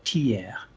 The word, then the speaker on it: Thiers: It's manufactured in an interesting city in central France called Thiers, that's how you pronounce it.